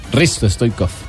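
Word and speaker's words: Hristo Stoichkov: En 1966 nace en Bulgaria Cristo Stoikov. – Risto Stoikov. – Bueno, ahí dice Cristo. – Bueno. – Pero dice Risto porque la H muda.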